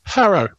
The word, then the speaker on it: Harrow: Harrow. I'm actually about 10 minutes from the famous school. – Say it again? – Harrow. – Oh, Harrod. Okay. – No, Harrow. H-A-R-R-O-W. – Harrow. – Correct.